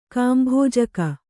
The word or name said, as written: ಕಾಂಭೋಜಕ